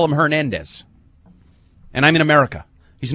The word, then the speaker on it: Hernandez: But whereas grammar cop Eric has been on the mispronunciation of Hernandez's name, he has yet to correct any of you on the intended pronunciation. In Spanish, the H is silent, so the name should be pronounced Hernandez, not Hernandez.